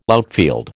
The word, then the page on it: outfield: American English